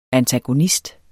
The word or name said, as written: antagonist